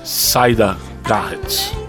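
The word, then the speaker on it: Siedah Garrett: Saida Garrett. É, a pronúncia certa é essa daí.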